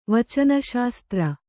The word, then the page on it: ವಚನಶಾಸ್ತ್ರ: Kannada